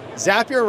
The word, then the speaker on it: Zapier: Zapier rhymes with happier.